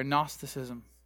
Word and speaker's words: Gnosticism: And it's, in English, we don't pronounce the G. We do that with a lot of Greek words. But in Greek, it's Gnosticism, if you can say it real fast.